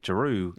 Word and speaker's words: Jaroo: also curious to see what Jeru had, not Drew, but Jeru. – Yeah. Yeah, for anybody in the chat, his name is spelled J-A-R-O-O. It's not Drew, like D-R-E-W.